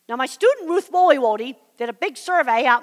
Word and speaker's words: Woiwode: And if you want to look that survey up online, her name is spelled Woliwode. It's a funny name. I'll spell it for you. W-O-I-W-O-D-E. Woliwode. W-O-I-W-O-D-E. Ruth Woliwode.